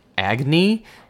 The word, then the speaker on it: Agnee: Agni, A-G-N-E-E.